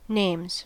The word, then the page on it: names: American English